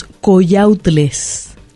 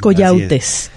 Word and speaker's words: coyautes: Coyautles, ahí le agregaron una L. – Una L, ¿verdad? Ajá, es coyautes. – Sí, la pronunciación de las personas varía. En este caso, la única que encontramos como referencia clara en un libro es coyautes, sin la L.